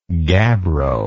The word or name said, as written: gabbroid